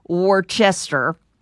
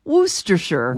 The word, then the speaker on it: Worcestershire: The mispronunciation is usually Worcester. – Yeah. – It is Worcestershire.